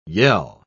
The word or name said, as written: yell